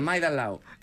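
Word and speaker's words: Maidalao (麦当劳): Aprendí a decir McDonald's, que era Maidalao.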